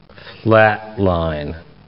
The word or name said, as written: flatline